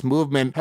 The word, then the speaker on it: MVMT: MVMT, pronounced Movement, has grown into one of the fastest growing watch brands with shipping with over 160 countries across the globe.